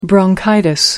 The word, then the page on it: bronchitis: American English